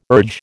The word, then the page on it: urge: American English